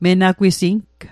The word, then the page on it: menagwising: Mi'gmaq